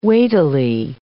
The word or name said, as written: weightily